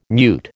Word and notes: mute: American English

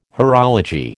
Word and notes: horology: American English

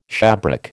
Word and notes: shabrack: American English

